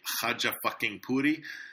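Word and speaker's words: Khachapuri: The journalist suggested Khajapuri. He said, it's time to make a Khajapuri.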